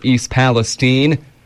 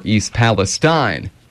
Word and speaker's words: East Palestine: Now, I've heard this town pronounced two different ways, either East Palestine or East Palestine.